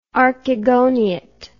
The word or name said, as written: archegoniate